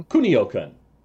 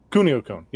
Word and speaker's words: Kunio-kun: Is that how you pronounce it? Kunio-kun? – Kunio-kun. – Kunio-kun, yeah. Because we had Renegade, River City Ransom, Super Dodgeball, then this game, and then later we got Crash and the Boys.